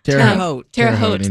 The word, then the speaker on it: Terre Haute: That one isn't, I'm going to say this wrong, Terre Haute, Indiana? – Terre Haute, thank you.